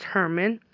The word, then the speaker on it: German: It's spelled G-E-R-M-A-N, but pronounced Herman.